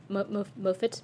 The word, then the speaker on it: Moffat: written by Moffat.